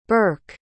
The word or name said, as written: birk